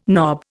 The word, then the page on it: knob: Indian